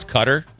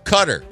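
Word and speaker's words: Qatar: Because she's always adamant to me that it's Cutter. She said her co-anchor in Los Angeles was a correspondent there before getting to the States and always said they pronounce it Cutter.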